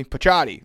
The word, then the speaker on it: Picciotti: Uh, Phil, I'm going to butcher it. You'll, you'll uh, fix it for me. Picciotti. Yes, there we go.